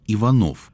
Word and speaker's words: Иванов: Я слышал, что сын Вячеслава Иванова, Дмитрий Вячеславович, предпочитал делать ударение в своей фамилии Иванов. Это правда или нет? – Сам я лично от него не слышал, как он ударял свою фамилию. Но так сложилось в кругах исследователей, что принято ударение Иванов, как себя называл поэт.